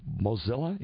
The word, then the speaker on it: Mozilla: Mozilla, is that how you pronounce that? – Yeah, Mozilla makes the Firefox browser and the Thunderbird email.